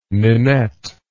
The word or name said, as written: Ninette